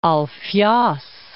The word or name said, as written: Alfios